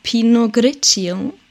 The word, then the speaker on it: Pinot Grigio: Und zwar einen Pinot Grigio.